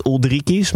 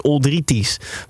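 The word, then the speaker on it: Uldriķis: En ik hoorde iedereen zegt Oldrikies. Maar als je het goed wil uitspreken, en Tim, dit is wel een keer van jouw straatje, is het dus Oldrities. Want in Let spreek je de K uit als T.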